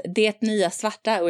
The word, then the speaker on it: Det Nya Svarta: You're doing a really good job pronouncing it there. It's Det Nya Svarta, which means the new black, which in Swedish basically only has the fashion connotations.